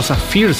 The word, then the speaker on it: Sapphire: Ed Sheeran, Sapphire, o Zafir sería ahora la pronunciación, ahí está, sí, Zafir.